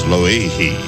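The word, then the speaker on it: Loihi (Lōʻihi): Aloha, Art, the proper pronunciation of our new island here in the islands, is Loihi. That's as close as I can get anyway.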